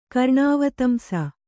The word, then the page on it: ಕರ್ಣಾವತಂಸ: Kannada